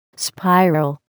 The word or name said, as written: spiral